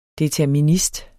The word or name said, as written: determinist